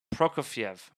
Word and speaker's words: Prokofiev: Prokofiev. – I think so, yeah. – Prokofiev. – Is he Russian? – He's Russian. Prokofiev. – I think Prokofiev, yeah. – Prokofiev. – emphasis on the last syllable.